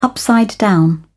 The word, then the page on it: upside down: British English